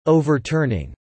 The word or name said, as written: overturning